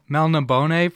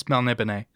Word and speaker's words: Melniboné: Yeah, I was pronouncing it Melniboné until I listened to one of the audiobooks that had an introduction from Moorcock, and it's Melniboné.